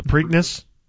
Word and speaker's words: Preakness: The Preakness? – Is that the way you pronounce it? – Yes.